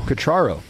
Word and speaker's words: Quatraro: Quatraro.